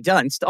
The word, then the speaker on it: Dunst: So Kirsten, pronounced correctly, Dunst, almost dies as well in the first 2 minutes of this movie.